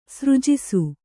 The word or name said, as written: ಸೃಜಿಸು